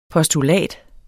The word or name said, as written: postulat